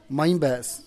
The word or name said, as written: maynbes